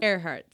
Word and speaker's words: Earhart: Porque no sé si lo voy a pronunciar bien, pero Amelia Earhart. Earhart. Earhart, sí.